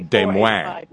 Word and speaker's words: Des Moines: I saw him in Des Moines, Iowa in 85, I guess, right? – Let's see. Yeah. Properly pronounced, that would be Des Moines. Yeah. Isn't that in good French, Des Moines? – Yeah, it should be.